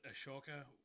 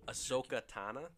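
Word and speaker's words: Ahsoka Tano: And on that note, as I called it, Ashoka, you pronounce it right now. – Ahsoka Tana. – Yeah, it's a new original series starring Rosor- Rosario Dawson, which yep.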